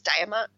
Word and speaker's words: Diamant: I'm not sure if I'm saying this right. I think it's Diamant. – Yes. Yeah.